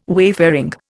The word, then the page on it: wayfaring: Indian